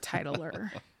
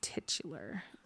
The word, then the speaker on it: titular: It's not pronounced titler. – I know. – It's pronounced titular.